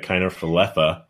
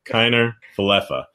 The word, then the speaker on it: Kiner-Falefa: And then since we're talking about the Rangers, we might as well just throw in Isaiah Kiner-Falefa. – That's how you pronounce it. Good to know. I've always wondered. I would call him Falefa. – Isaiah Kiner-Falefa.